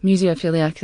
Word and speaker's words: Museophilliac: Am I saying that right? – Museophilliac, that's Muse- good.